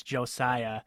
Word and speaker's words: Josiah: It's Josiah, not Josiah. I don't know if anybody else listening was watching the broadcast, but they just kept butchering his name and calling him Josiah. Josiah Price. Some people kind of like say it with like a Z, like Josiah, but it's Josiah.